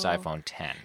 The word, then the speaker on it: iPhone X: They released the iPhone 10, which is really the iPhone X, but it's Roman numeral, so it's pronounced iPhone 10.